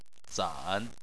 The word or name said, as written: zan3